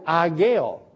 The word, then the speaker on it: Haggai: The H at the beginning is mute, and so they pronounce it Ageo. That's the way they pronounce it in the Hebrew.